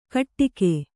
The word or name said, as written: ಘಟ್ಟಿಕೆ